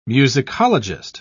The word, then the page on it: musicologist: British English